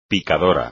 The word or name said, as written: picador